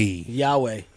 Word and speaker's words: Yahweh: Y-A-H, that's hard to say it. Y-A-H? – W-A. – W-A. – Yahweh, yeah. – Something like that. But it's Rich Woods on, what am I doing? First of all, I should just put the Yahweh. Y-A-H-W-E-H. Dude, I'm reading it. It's hard to say. – Y-A-H-W-A-H.